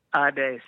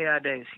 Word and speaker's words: Adès: I think that's how you say his name. Adès. It's definitely Adès. He's French.